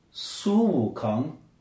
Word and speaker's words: Sun Wukong: Repeat after me. – Okay. – Su. – Su. – Wu. – Wu. – Kong. – Kong. Su Wu Kong.